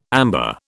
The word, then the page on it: amber: British English